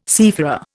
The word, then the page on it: seafarer: Indian